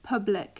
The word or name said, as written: public